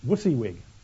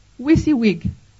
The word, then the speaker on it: WYSIWYG: It's like this, WYSIWYG. – WYSIWYG.